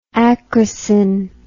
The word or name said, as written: acrosin